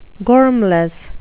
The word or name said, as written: gormless